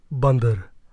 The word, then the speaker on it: Bandar: No, it's Bandar. But nobody's ever going to be able to say Bandar. It rhymes with under, but it's like Bandar.